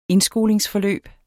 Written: indskolingsforløb

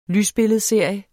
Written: lysbilledserie

